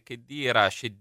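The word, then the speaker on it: Cheddira: E poi ancora Cristian: dopo la notizia che Chedira dovrebbe essere la pronuncia corretta,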